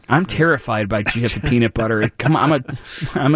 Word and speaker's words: GIF: So GIF is actually pronounced GIF, as they're saying. GIF, not GIF, like a GIF is a peanut butter, I suppose.